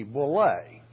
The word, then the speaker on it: boule: B-O-U-L-E, boule, pronounced boule in the Greek.